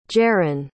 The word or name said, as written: jeren